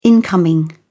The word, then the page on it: incoming: British English